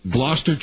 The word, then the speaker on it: Gloucester: Yep, Gloucester.